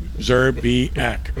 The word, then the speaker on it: Szczerbiak: I'm a write it out for you phonetically. You know, the S becomes the Z. You know, the Z becomes the S. It's one letter. Mm-hmm. Szczerbiak, okay? It's Szczerbiak. Szczerbiak.